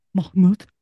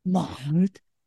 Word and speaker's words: Mahmoud: Mahmoud. – Yeah. It's just, she gets the guttural. – Mahmoud.